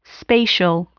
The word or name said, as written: spatial